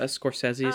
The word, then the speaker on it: Scorsese: I can't speak for you, but I am not a Scorsese fan no. – at all. Is that how you say his name? – Yes, yeah, Scorsese. Scorsese.